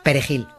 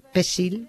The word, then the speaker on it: perejil: Los soldados de Trujillo detectaban a los que había que matar haciéndoles pronunciar la palabra perejil, porque aunque supieran castellano, esa palabra no sabían pronunciarla. Y si decían, en vez de perejil, decían pesil, pues, que es como lo llamaban, sí, pesil, sí, lo agarraban y lo mataban.